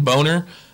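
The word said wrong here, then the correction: Bonnar: I'm pretty sure it's pronounced Bonner, but he's a UFC Hall of Famer.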